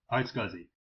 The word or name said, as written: iSCSI